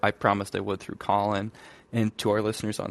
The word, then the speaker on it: Callin: It's spelled Callin. If you forget what it's called, just think about what you're going to do on the app. You're going to call into the show.